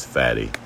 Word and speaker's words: Fatty: Some people refer to me ever since I was born as Fatty. You know, that's F-A-T-T-Y, F-A-T-T-I-E, however you want to spell it,